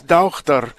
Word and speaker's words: daughter: For instance, daughter was Dauchter, which is very close to our roots as a Germanic language.